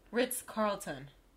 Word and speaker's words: Ritz-Carlton: Ritz Carlton.